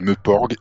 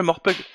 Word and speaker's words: MMORPG: Alors, non, c'est pas le Morpeug. C'est le Meuporg, mais Meuporg, ça s'écrit MMMPORPG, c'est pour ça que...